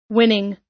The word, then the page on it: winning: American English